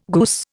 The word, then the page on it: goose: Indian